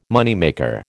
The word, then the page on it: moneymaker: American English